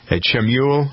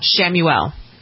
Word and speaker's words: Chamuel: So the first finalist is Michelle, and you've pronounced this correctly, Chamuel. – Chamuel. – Why don't they spell it with an S then? – I don't know.